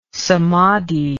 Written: samadhi

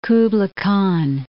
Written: Kublai Khan

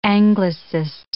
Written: Anglicist

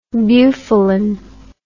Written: bufalin

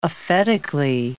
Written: aphetically

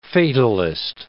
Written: fatalist

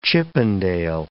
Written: Chippendale

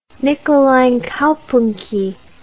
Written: Nikolainkaupunki

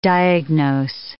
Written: diagnose

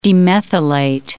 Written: demethylate

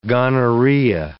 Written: gonorrhea

